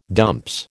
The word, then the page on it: dumps: American English